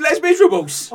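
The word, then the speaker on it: Les Misérables: I think you'll find, Governor, that it's pronounced Les Miserables.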